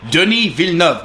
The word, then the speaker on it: Denis Villeneuve: Denis Villeneuve, motherfuckers.